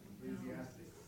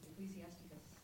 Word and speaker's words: Ecclesiasticus: Am I saying it right? – Ecclesiastes. – No, that's the wrong one. – Ecclesiasticus.